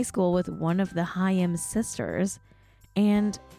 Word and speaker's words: Haim: Haim is playing a tour sponsored by a credit card company where they go to a bunch of delis.